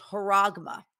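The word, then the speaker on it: charagma: I will tell you this, that in the book of Revelation, The word that is used for mark in Mark of the Beast is haragma. It's C-H-A-R-A-G-M-A, I believe is how it's spelled.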